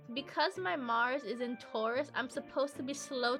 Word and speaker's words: Taurus: Is that how you pronounce it, Taurus? – Taurus. – My bad. Taurus.